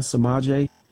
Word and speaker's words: Semaje: I'm on Instagram, TikTok, Facebook, Find Samaje. My name is spelled S-E-M-A-J-E. My name is actually James Backward at with an E. So just say E James Backward, spell it backwards, and that's how you can find me.